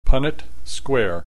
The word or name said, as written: Punnett square